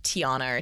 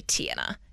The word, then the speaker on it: Tiana: Everyone always says Tiana or Tiana. It's actually Tiana. Emphasis is on the first syllable.